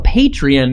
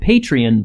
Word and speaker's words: Patreon: Just popping in a little early before the episode to tell you guys that we have a Patreon now, and it's very exciting. I just pronounced it Patreon, but it's actually Patreon.